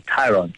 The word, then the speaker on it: Tayron: I was trying to think of how you say it properly, but it's actually Tayron.